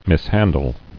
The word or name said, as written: Mishandle